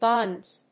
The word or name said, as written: bunds